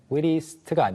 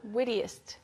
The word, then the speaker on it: wittiest: 그리고 재치 있는 거 witty, 언변 좋은 거 eloquent 설명드렸는데 발음 하나만 보자면 witty 같은 경우에는 witty로 원래는 끝났잖아요. – Mm-hmm. – 근데 뒤에 이제 est를 붙이면 wittiest가 아니고 wittiest. – 어가 살짝 들어가요.